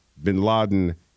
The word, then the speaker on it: Bin Laden: A 22-year-old pro soccer player from Peru is drawing notice for his unusual name, Osama Bin Laden Jimenez Lopez, whose second name is pronounced with a Spanish B. So he's Osama Bin Laden Jimenez Lopez.